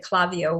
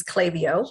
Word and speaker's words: Klaviyo: I, I think I pronounced it Clavio when I first introduced you. My apologies, Clavio.